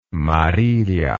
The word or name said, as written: Marilia